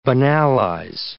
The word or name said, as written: banalize